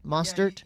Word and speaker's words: Mostert: Did I say his name right? Mostert? – Yeah, you did.